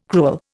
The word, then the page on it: crewel: Indian